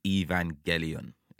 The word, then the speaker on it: Evangelion: But yeah, it is definitely hard G, and the Gelion of Evangelion is definitely a hard G.